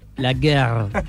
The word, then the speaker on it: la guerre: La guerre. – Estamos bien, gracias. – Con esa R tan francesa que es re difícil de pronunciar.